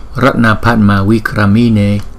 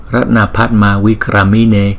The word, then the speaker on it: Ratna Padma Vikramine: Bảo Hoa Du Bộ Phật chúng ta sẽ phát âm là Ratna Padma Vikramine, Ratna Padma Vikramine, Ratna Padma Vikramine.